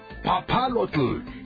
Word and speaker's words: papalotl: La palabra papalote, mis panteritas, es de origen náhuatl. Sí, se pronuncia papalotl y significa mariposa.